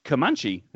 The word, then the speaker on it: Comanche: The first one was Comanche.